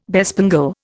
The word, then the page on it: bespangle: Indian